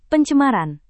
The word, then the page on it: pencemaran: Indonesian